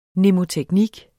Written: mnemoteknik

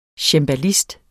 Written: cembalist

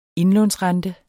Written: indlånsrente

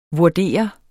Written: vurdere

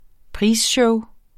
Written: prisshow

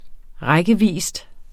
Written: rækkevis

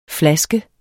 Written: flaske

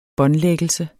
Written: båndlæggelse

